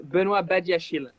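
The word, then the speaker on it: Benoît Badiashile: There's been reports that United are still interested in Monaco's Benoit Bernardeschiel. What's the latest on that? Forgive my pronunciation, by the way. – No. The name in French is Benoit Badiachil.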